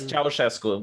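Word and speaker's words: Ceaușescu: Because that, so communism, that's Ceausescu, is that how you say his name? Ceausescu? – Yes, Ceausescu was the dictator.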